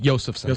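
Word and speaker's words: Josefson: It's I checked the pronunciation guide. Josephson.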